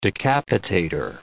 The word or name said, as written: decapitator